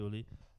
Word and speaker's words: Pioli: And Pioli, right? That's how it's pronounced. Yeah. Pioli lost 2-1 to Roma, who moved up to fifth place at the table.